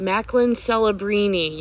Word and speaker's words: Macklin Celebrini: Macklin Celebrini, your projected first overall pick for the 2024 NHL draft.